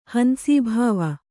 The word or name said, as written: ಹಂಸೀಭಾವ